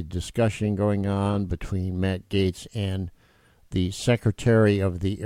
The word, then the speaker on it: Gaetz: Matt Gaetz.